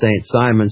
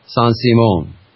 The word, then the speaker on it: Saint-Simon: And just when you say it, you can say it for clarity and phonetically on air as St. Simon so that people know. But it's pronounced, from the best advice that I've had, Saint-Simon.